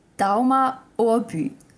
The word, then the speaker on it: Dagmar Overbye: Oberje? – Wait, what? – I don't know. Oberbe? – Okay. It's actually pronounced Dauma Orbu. – Oh. okay. – It took me completely off guard, but Dauma Orbu.